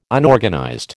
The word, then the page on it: Unorganized: American English